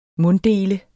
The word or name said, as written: munddele